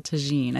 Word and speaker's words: tagine: But there's a recipe you sent us for a Moroccan vegetable tagine.